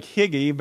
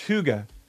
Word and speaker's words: hygge: It looks like Higgy, but it's pronounced Huga.